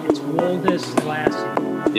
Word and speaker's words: Woldeslassie: Twitter is just my name, Abe Woldeslassie. People can spell that 12 letters, but it's easy when you look at it. It looks hard to pronounce. When you break it down, it's Woldeslassie.